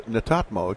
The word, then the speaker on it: Netatmo: Our next guest is the marketing product manager with a company called Netatmo.